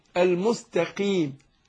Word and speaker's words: المستقيم: السين ناخد بالنا من الترقيق اللي فيها، المستا ما هوش مستقيم طا مستا، لا مستا مش مستا، المستقيم قيم، والقاف زي ما قلنا ايه؟ مكسوره فبتاخد ادنى درجات تفخيم، المستقيم قيم.